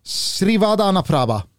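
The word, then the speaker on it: Srivaddhanaprabha: Leicester-ägaren då, Ayavat Srivad... – Nej. Jo. – Srivadanaprabha. – Bra! – Srivadanaprabha. – Han har med att jobba in det. – Srivadanaprabha är ju då sonen till den framlidne Vishay Srivadanaprabha.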